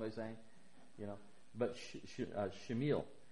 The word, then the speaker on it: Shemuel: Now Samuel, his name that she would name him, actually, I think it's interesting, the Hebrew name is pronounced Shemuel.